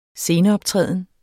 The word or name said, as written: sceneoptræden